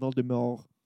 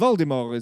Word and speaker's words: Voldemort: Well, the T is silent. – The T is silent in the French fashion, Voldemort. – Oh, okay. – I believe Voldemort is back.